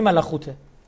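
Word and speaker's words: ملخوته (מלכות): اسمش هم دقیقاً در عبری ملخوته، همون که اینا خ تلفظ میکنن، مثلا همین خاخام یعنی حکیم.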